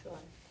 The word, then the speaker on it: Shuar: Shuar. It's spelled S-H-U-A-R. – S-H-A-U-R? – S-H-U-A-R.